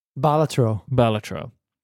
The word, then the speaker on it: Balatro: But I put it down and I've immediately picked up Balatro, which is how I keep saying it. How should I say it? – Balatro. Yeah.